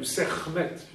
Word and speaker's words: Sekhmet: Alors, vous avez fait le rapprochement avec Sekhmet ou Sekhmet, puisque les cas sont prononcés plutôt.